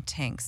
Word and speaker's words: Tinx: All channels, it's me, Tinks. So everybody calls me Tinks. That's my nickname. – Spell it for our stoners. – T-I-N-X, and you can find me at It's Me Tinks, I-T-S-M-E-T-I-N-X.